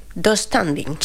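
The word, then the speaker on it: döstädning: Yo lo pronuncio... Do standing. – Sí, así. Tengo esa silla de IKEA. Sí, se pronuncia así.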